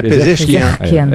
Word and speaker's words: Pezeshkian: O presidente do Irã, Massoud, a pronúncia certa é Pezeshkian, é isso? – Pezeshkian.